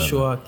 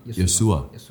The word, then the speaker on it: Joshua: First of all, sorry, how do you say his name? Because you say different. – Yoshua Kimmich. – Yosua. I didn't know that.